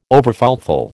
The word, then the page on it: overfulfil: American English